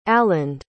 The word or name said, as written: aland